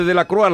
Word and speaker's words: Delacroix: Entonces, el famoso, ahora que lo dice usted, el famoso cuadro de Delacroix, La libertad guiando al pueblo, es una mujer que va en bata, ¿no? – Delacroix, sí, pronuncie usted bien el francés, Jesús.